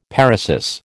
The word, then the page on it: paresis: American English